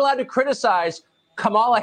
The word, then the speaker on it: Kamala: Her name is pronounced comma, like the punctuation mark. Look, Kamala. Okay. Seriously, I've heard every sort of bastardization. – So what? – That's how it is, Kamala.